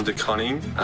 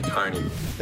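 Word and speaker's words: De Koning: My name's definitely not pronounced Tom DeConning. Um, it's from Dutch heritage, and yeah, it's definitely DeConing.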